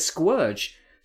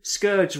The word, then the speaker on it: Scourge: And then in the movie, yeah, Unicron says Squirge. So all I can assume is he's actually called Squirge. We've been calling him Scourge wrongly for years. – Yep. – We've all been saying it wrong.